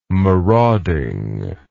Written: marauding

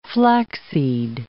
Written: flaxseed

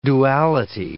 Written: duality

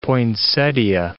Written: poinsettia

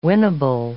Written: winnable

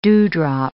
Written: dewdrop

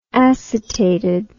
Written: acetated

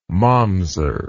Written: momser

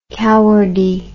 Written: cowardy

